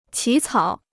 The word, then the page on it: 起草: qǐ cǎo